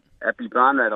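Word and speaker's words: Aphibarnrat: I did, Apibarnrat.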